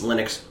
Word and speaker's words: Linux: Is it pronounced Linux or Linux? – Linux.